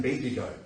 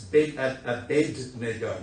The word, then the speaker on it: Abednego: I have to apologise because if I don't focus when I say his name, I end up saying Bendigo. But it's Abednego, not Bendigo.